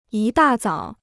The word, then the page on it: 一大早: yī dà zǎo